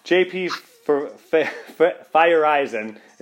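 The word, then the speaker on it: Feyereisen: J.P. Fireisen is how it's pronounced, and David Phelps.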